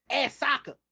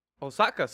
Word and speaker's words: Ahsoka: I didn't say Ahsoka. Now I didn't say that. I said Ahsoka, like you say her name. Nice try though. – You said Osaka, so I was like, I didn't say anything.